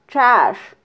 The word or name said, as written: txax